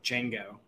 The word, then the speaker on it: Django: My mind immediately went to Django, with the D being silent and everyone calling it Django.